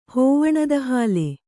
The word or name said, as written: ಹೋವಣದಹಾಲೆ